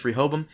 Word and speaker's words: Rehoboam: allows Martin and Bernard to access Rehoboam. Martin sends Dolores files on Sarek from Rehoboam before sending every individual on the planet the data and plans Rehoboam has on them, and the world descends into chaos, which I found hilarious.